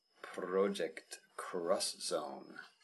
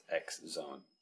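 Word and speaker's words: Project X Zone: And Namco Bandai did have a very awesome game though, and that was Project Cross Zone, or not X Zone, as most people might think it would be called. For some reason, in Japanese, X is pronounced cross.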